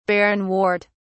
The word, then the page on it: barrenwort: British English